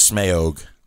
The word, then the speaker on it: Smaug: That Smaug has. – Smaug. – Yeah, that's how you pronounce it.